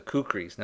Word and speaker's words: kukri: which was the kukris. Now, some people will uh, pronounce it uh, kukri there's there's different things. I've always kind of called them kukris.